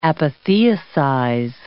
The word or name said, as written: apotheosize